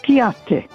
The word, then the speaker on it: kwiaty: Polish word for flowers, plural, is kwiaty, spelled K-W-I-A-T-Y. Again, that's pronounced kwiaty.